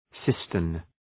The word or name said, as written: cistern